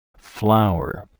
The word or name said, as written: flour